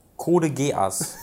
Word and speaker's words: Code Geass: Wie, wie, spricht man das aus? Du wusstest es mal. Code Geass. ja, danke schön.